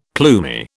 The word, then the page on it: plumy: British English